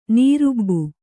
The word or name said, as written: ನೀರುಬ್ಬು